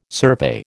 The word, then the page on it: survey: American English